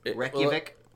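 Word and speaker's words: Reykjavík: Reykjavik? – Well, ooh, that was pretty good.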